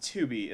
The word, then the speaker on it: Tubi: Did you say Tubby TV? – It's pronounced Tubi, isn't it? – It's pronounced Tubi. – But it's spelled like tuba, so it must be Tubi, right? – You didn't say Tubi, you said Tubby, like Tubby Custard, like you're a fucking...